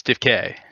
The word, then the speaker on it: DFK: Yes, it's French. It's DIFK. – DIFK, yeah. Dif- no, it's DIFK.